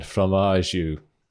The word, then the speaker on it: Fromageau: It's like cheese and then an AU at the end, Fromageau.